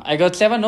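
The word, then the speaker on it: IGOT7: Que es pronuncia um, I Got Seven o Ah Gaze.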